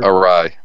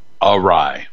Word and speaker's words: awry: How do you say that word? – Awry. – Yeah, there you go. Easy for you. Awry.